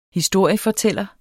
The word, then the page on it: historiefortæller: dansk